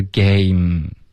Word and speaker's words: game: 没有一个 m, OK, 虽然它的就是结尾有一个 e 的这个英文可是它是不发音的。所以它的发音是 game,